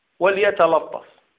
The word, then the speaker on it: وليتلطف: ولكن هي لا وليتلطف وليتلطف الطاء مفخمة ولا، لكن قد يرقق اللام فيرقق الطاء يقول وليتلطف تف وليس الطف طاء.